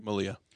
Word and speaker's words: Malia: Malia.